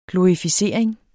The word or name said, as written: glorificering